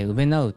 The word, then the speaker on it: 肯う: 自己肯定感のこう、こうの字、肯定のこうってうべなって読むんですよね。